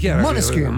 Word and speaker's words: Måneskin: Moneskin. – Bravo, ottima pronuncia.